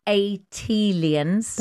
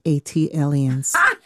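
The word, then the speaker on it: ATLiens: Tayari, just as Jill did for Philly, as an unofficial ambassador for Atlanta and Atlians. – That is pronounced Atlians. – Thank you. – Like Atlanta alien, Atlian.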